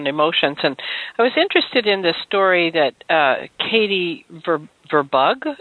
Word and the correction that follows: Verberg: is that how you say it? – Oh, Verberg, yeah.